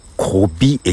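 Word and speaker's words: 古井: 古いという字に井戸の井なんですけども、だから古い駅かな、濃い駅かなとか思っていたら、こび駅と読むらしくてですね。まあ朝来て、それが一番の衝撃だったんですこれこびって読むんだと思ってね。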